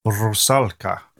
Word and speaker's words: Rusalka: And now, you might be able to help me with the pronunciation of this, my love. Mm. The Rusalka, a Russian mermaid. You could probably make it sound far more Russian. – Rusalka. – There we go.